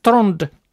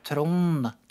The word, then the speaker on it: Trond: Ja, Trond. Och jag tror det är väldigt betoning av den D:n då, som för exempel visst en, ja, som var från Östlandet, han säger nästan inte D.